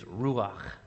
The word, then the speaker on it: ruach: And that name in Hebrew is ruach. And I invite you to say this word with me Ruach. You got to use your, use your voice for that last syllable Ruach. Yeah, there you go.